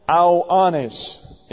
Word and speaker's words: Ioannes: Here is the name John. I-O-A-N-N-E-S. Ioannis is the way that would be pronounced. Ioannis is John in the Greek.